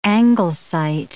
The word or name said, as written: anglesite